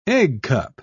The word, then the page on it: eggcup: British English